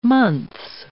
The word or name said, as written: months